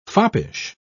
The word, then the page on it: foppish: British English